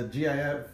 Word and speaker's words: GIF: So it's a hard G. – It's GIF.